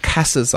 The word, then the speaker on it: CASA: We all call it CASA, and I know it sounds a bit strange when it doesn't spell that way.